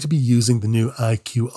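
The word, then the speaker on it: EyeQ: Its product line is called IQ. It sounds like the letters that stand for intelligent quotient, but it's spelled like the word I, implying a combination of machine vision and artificial intelligence.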